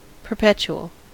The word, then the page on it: perpetual: American English